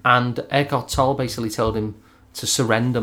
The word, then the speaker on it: Eckhart Tolle: And I saw this question of Eckhart Tolle. Am I pronouncing his name right? – Eckhart Tolle, yeah.